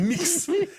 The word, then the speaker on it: Mx: MX, mais ça se prononce mix.